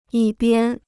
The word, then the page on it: 一边: yī biān